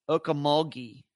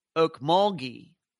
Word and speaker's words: Okmulgee: Twinkle's telling me Okmulgee, hard G, Okmulgee. All right, thank you, Twinkle. That's how you pronounce it.